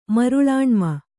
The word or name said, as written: ಮರುಳಾಣ್ಮ